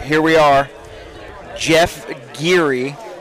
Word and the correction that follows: Geary: It's pronounced Gary, as if it was G-A-R-Y.